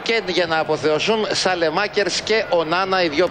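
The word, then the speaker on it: Saelemaekers: Αυτός λέγεται Σαλεμάκερς ή Σολεμάκερς, ρε παιδιά. – Σα, είναι ΑΕ. – Άμα είναι ΑΕ, είναι όμικρον. Διαβάζεται όμικρον.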